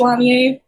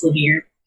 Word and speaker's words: Lanier: And then my last name, I guess, is technically French, so it would be pronounced Lanier, but it's pronounced Lanier over here, so...